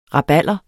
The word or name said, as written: rabalder